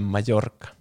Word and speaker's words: Mallorca: Se on niinku se Mallorca lausutaan Mallorca.